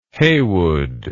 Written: Haywood

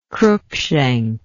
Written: cruikshank

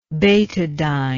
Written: betadine

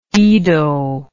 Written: ido

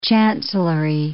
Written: chancellory

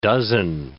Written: dozen

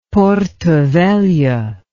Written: Porto Velho

